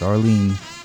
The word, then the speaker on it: Arline: The Starlighters and Airline. No, I'm sorry, it's Arlene. – Wow. – But it's spelled, if you just put another I in after the A, it would be Airline. Because Arlene is usually spelled A-R-L-E-N-E, right? – Yes. – But this is spelled A-R-L-I-N-E.